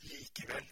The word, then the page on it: pourtant: [puʁ.tɑ̃]; France